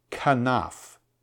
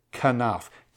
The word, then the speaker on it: kanaph: The wings there is the word kanaf. Kanaf. K-A-N-A-P-H.